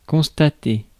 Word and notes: constater: France; [kɔ̃s.ta.te]

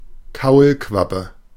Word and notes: Kaulquappe: /ˈkaulkvapə/; /ˈkaulkwapə/

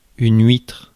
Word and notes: huître: [ɥitʁ]; /ɥitʀ/

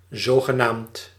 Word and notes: mutmaßlich: /ˈmuːtˌmaːslɪç/

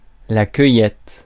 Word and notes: gathering: /ˈɡæ.ðə.ɹɪŋ/; American English

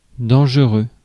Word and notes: harmful: American English; GenAm; /ˈhɑɹmfl̩/

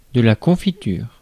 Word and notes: jelly: /ˈdʒɛl.i/

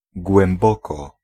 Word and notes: djupt: /jʉːpt/

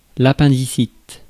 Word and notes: appendicite: France; [a.pɛ̃.di.sit]

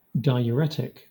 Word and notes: diuretic: /daɪ(j)əˈɹɛtɪk/

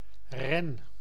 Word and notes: ren: /rɛn/

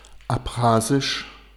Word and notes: abchasisch: /apˈχaːzɪʃ/

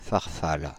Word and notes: farfalla: /far.ˈfal.la/

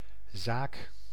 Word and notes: zaak: /zaːk/

